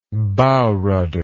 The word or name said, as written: bow rudder